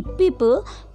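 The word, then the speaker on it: people: पीपल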